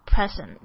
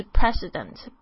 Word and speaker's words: President: 好，还有一个就是特别要注意，就是这个 President 常常有同学会念成 Present，不一样，它不是礼物，是总统，是 President，不是 Present， Present 是礼物， P-R-E-S-E-N-T 完全不同的东西。